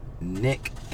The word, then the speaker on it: Nic: My name is Nick Nation from Nation Entertainment. You can find us on Instagram, Nation underscore Entertainment, exactly how it is spelled, and on uh, Snapchat. You can find me, Nick N I C dash Nation.